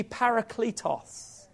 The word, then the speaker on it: Parakletos: For if I do not go away, the Helper, say with me, Parakletos. Come on, you've got to say it like really posh Parakletos.